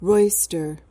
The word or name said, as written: roister